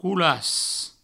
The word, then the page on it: colas: coulas